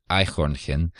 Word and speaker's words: Eichhörnchen: Oh, and he challenged us to say the German word for squirrel, which challenge accepted, I believe, is Eichhörnchen, if I said that right. Yeah. – Yeah, that's pretty good.